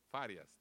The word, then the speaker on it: Farias: Y damos la bienvenida a la hermana Marisol Frías, que está aquí con nosotros. Iba a decir el apellido, pero no lo puedo pronunciar bien. ¿Frías? Farias. Farias, dijo. Hermana Marisol Farias, sea bienvenida a nuestro servicio de español.